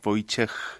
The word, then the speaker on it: Wojciech: Je m'appelle Wojciech, c'est un peu difficile à prononcer pour les Français parce qu'en France, H on ne prononce pas, en Pologne on prononce, comme dans les autres pays, comme H, Wojciech.